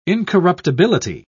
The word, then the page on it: incorruptibility: British English